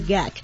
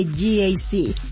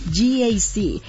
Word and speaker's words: GAC: No se dice GAC, se dice GAC. Nuevamente, GAC.